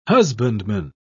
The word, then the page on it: husbandman: British English